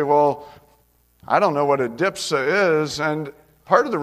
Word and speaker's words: adipsia: And by the way, that's spelled A-D-I-P-S-I-A. So it looks more like adipsia, but that's not the way the pronunciation guide on the internet says it.